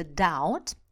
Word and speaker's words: doubt: Вообще, это слово произносится doubt, doubt, и оно так произносилось довольно долго.